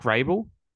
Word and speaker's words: Vrabel: What's his name? Mike Vrabel? – Yeah, you got it.